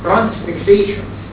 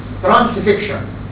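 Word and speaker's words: transfixion: ترانسفيكشن احيانا تنطق ترانسفيكسيشن لكن هي الصح قوي ترانسفيكشن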